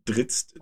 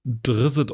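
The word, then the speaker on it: Drizzt: Und man kann auch äh, zum Beispiel den sehr beliebten, äh, wie, wie sagt man auf Deutsch, äh, Schwarzelfen, äh, äh, Dritzt äh, freispielen. Drisset O'Durden, ja. – Ich habe ihn gerade äh, deutsch ausgesprochen.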